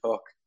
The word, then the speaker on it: Hoek: Um, so we're called Hoek Home and spelled H O E K, um, pronounced like hook or book.